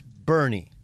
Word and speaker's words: Burnie: But it's spelled B-U-R-N-I-E. Right, because it's Heat, so it's Bernie.